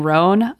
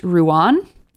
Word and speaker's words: Rouen: I kept saying Rhone, I believe, but according to Emma, it is actually pronounced Rouen.